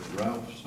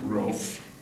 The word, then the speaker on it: Ralph: I'm always intrigued when I find somebody's name is spelled R-A-L-E-H, because some people call it Ralph, some call it Rolf, and some call it Rafe. I believe Rafe is the oldest form of it.